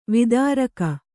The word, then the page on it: ವಿದಾರಕ: vidāraka